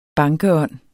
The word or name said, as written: bankeånd